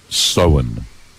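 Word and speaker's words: Samhain: But it is actually, it's actually the Irish word for Halloween, spelled S-A-M-H-A-I-M. But it's pronounced sewn.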